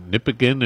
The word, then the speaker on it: Nipigon: It's near Nipigon. It's on the north shore of Lake Superior. So it's a pretty good drive. It's about 8 or 10 hours north of Sault Ste. Marie. – Nipigon is how you pronounce it? – Yes, yes.